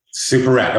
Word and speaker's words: Superette: Superit.